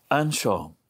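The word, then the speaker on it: anseo: And the role was conducted with the answer to whether or not one was present in Irish, Anshaw, which of course is Which is pronounced in a slightly different way in Dingle, I believe. But I'm going to pronounce it in the Donegal fashion because I'm from up that neck of the woods.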